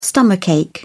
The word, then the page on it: stomach ache: British English